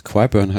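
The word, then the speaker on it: Qyburn: Und auf einmal meldet sich die rechte Hand der Königin, den Kyborn. Ich muss gestehen, der Name sagte mir eigentlich gar nicht so wirklich wieder was. – Oh ja, doch, doch. Quiburn heißt er übrigens, wird in der Serie ausgesprochen.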